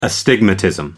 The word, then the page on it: astigmatism: American English